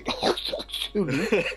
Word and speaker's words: Cthulhu: Non, normalement c'est Cthulhu, d'accord. – C'est bien guttural.